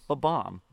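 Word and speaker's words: Bob-omb: Ba-bomb? – Ba-bomb.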